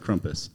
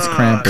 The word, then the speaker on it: Krampus: Krampus. How do you say it? – Krampus. – I think we're going with Krampus. – Krampus. – Krampus. – Like, I don't know what it really is. – It really is Krampus.